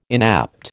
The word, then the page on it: inapt: American English